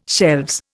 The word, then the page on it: shelves: Indian